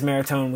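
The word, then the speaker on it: Motagua: Yes, it is Maratone. We pronounced that wrong all last episode.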